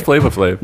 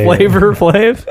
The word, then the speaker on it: Flavor Flav: But hard R on flavor. – No, it is. It's spelled flavor. – Oh, I thought it was Flava Flav.